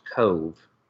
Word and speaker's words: Cobh: The first port was Cove, and they spell it COBH. And it's in Ireland, and apparently in the Irish language, they don't have a V. So it's COBH, but it's pronounced Cove.